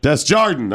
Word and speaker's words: Desjardin: Desjardin.